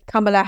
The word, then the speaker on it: Kamala: So it's like that with Kamala Harris. In one of her interviews, she said it was like comma, as in the punctuation mark.